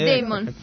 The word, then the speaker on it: Damon: Damon, perché l'ho letto su Wikipedia, come si pronuncia? – Sì, Damon, va bene.